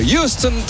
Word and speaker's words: Houston: il faut prononcer à l'américaine les noms d'avis, le Houston, Texas.